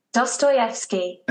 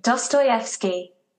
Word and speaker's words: Dostoevsky: You listened to the pronunciation and still said it wrong. – Dostoevsky. – Play the pronunciation. – Dostoevsky. – Okay. I guess you said it right.